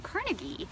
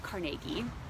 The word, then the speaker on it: Carnegie: But it's Andrew Carnegie, or if you pronounce it Carnegie, I've heard it both ways.